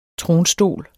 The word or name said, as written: tronstol